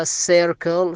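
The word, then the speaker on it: circle: بعد كلمة كير كرين بعدها سيركل، شوفوا لو لاحظتوا في السيركل حرفين سي سي، السبب الأول اللي نطقنا حرف السي في كلمة سيركل أتى بعد حرف السي آي، لذلك نطقناه بحرف السا. الحرف الثاني اللي هو السي نطقناه بحرف الكاف لأن أتى بعد حرف ال، والال ليس من إحدى الحروف الثلاثة.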